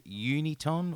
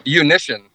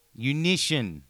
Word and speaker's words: Unition: Is it Uniton or Uniton? – Unition. – Unition.